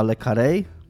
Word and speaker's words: Le Carré: E, serial jest adaptacją powieści autora, którego dowiedziałem się mm, słuchając o tym serialu i oglądając, że cały czas wymawialiśmy źle jego nazwisko, e, czyli Johna Le Carré, e, a nie Lekar, jak mówiliśmy zawsze. E, bo tam jest akcent tym.